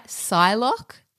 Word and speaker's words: Psylocke: She is in the X-Men where she plays, am I going to say this right, Psylocke? – Yes, that's right.